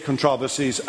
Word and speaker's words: controversies: The controversies, the, the emphasis is on the first syllable.